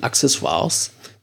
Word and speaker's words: Accessoires: So heißt es tatsächlich laut Aussprache Duden, Accessoires. So leicht das erste C angetippt.